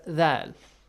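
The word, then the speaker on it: dhal (ذال): How to say the letter dhal? Okay? – So dhal. You just say th, bite your tongue, bite the tip of your tongue and blow air.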